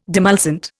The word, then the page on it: demulcent: Indian